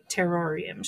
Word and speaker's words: Terrorium: And so I've been diving into these other crystals that I got from the Terrorium shop. And yes, I'm saying it that way because they spell it like terror, not terrarium, just in case anybody thought I was mispronouncing it.